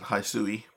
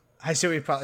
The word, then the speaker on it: Hisui: Hisui is probably more correct. I always say Hisu, but that's probably wrong because it sounds like a cat hissing.